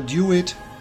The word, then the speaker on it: DeWitt: Aber natürlich hören sich Booker DeWitt, ja im Englischen wird er so ausgesprochen, nicht Herr DeWitt, und Elisabeth im Original ebenfalls klasse an.